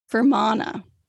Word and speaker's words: Fermanagh: I know I'm saying that right. I looked up how to pronounce it. And it's like Fermanagh.